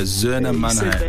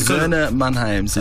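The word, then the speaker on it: Söhne Mannheims: Yeah, Zerna Mannheim. – You said that right.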